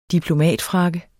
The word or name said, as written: diplomatfrakke